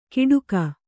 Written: ಗಿಡುಗ

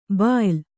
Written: ಭಯ್ಯಾ

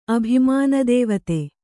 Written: ಅಭಿಮಾನದೇವತೆ

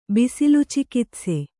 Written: ಬಿಸಿಲುಚಿಕಿತ್ಸೆ

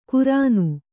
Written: ಕುರಾನು

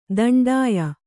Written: ದಿಂಡಿಯ

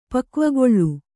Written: ಪಕ್ವಗೊಳ್ಳು

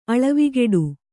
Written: ಅಳವಿಗಿಡು

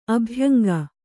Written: ಅಭ್ಯಂಗ

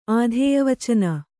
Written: ಆಧೇಯವಚನ

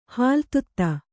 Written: ಹಾಲ್ತುತ್ತ

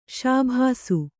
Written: ಶಾಭಾಸ್

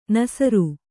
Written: ನಾಸೀರ

